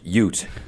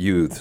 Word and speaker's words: Ute: My cousin Vinny, it's pronounced Ute, okay. Not youth.